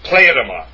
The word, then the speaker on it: pleroma: pleroma, and that's how it's pronounced. The accent is on the first syllable, pleroma.